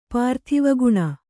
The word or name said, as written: ಪಾರ್ಥಿವಗುಣ